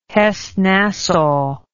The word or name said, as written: hessen nassau